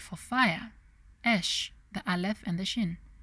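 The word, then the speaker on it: esh (אש): In Hebrew, the word for fire is that Hebrew letter pronounced as esh, while the word for man pronounced as ish, and the word for woman is pronounced as isha.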